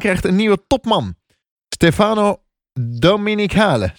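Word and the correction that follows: Domenicali: Zeg ik dat goed? Dom Dominicali. – Dominicali. – Dominicali. Dominicali. Ja.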